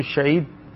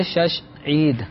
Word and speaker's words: شش عید: تو اصل لفظ ہے شش عید.